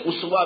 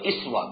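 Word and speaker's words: اسوہ: یہ نوٹ کر لیجئے کہ اسوا جو لفظ ہے اس کے 2 تلفظ ہیں, اسوا بھی اور اسوا بھی, اور بالکل پیرلل اس کے الفاظ ہیں قدوہ اور قدوہ.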